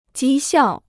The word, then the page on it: 讥笑: jī xiào